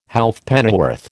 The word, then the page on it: Halfpennyworth: American English